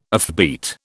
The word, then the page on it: offbeat: British English